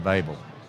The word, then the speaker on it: Babel: And you had it right the first time. It's Creation of Babel.